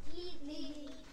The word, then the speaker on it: Lied: El Lied es una palabra alemana que significa canción. ¿Cómo se dice? – Lied. – Lied. Y bien. Se escribe L-I-E-D.